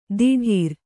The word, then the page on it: ದಿಢೀರ್: diḍhīr